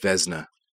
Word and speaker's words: Vezina: And it's pronounced Vezina. – Vezina. – Just Vezina. Vezina. Like there's no I. – So you skip, but there is an I. You just skip it. It's a silent I.